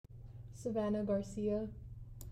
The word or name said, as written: Savannah Garcia